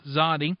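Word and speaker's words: Tzaddi: And yes, I know it's T-Z-A-D-D-I, but it's pronounced Zadi.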